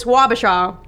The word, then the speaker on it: Wabasha: W-A-B-A-S-H-A.